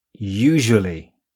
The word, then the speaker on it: usually: usual usually 大家注意到了吗？ usual 和 usually 当中的浊辅音 r 这样读 r。